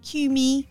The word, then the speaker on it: Cumie: Cumie. Yeah. – Spell it. – C-U-M-I-E. Cumie.